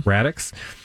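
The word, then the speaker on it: Raddix: Radix. Rhymes with Maddox.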